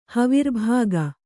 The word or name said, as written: ಹವಿರ್ಭಾಗ